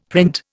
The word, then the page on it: print: Indian